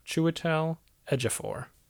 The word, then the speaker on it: Chiwetel Ejiofor: I say that right? – I believe you did. Chiwetel Ejiofor.